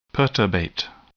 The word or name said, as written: perturbate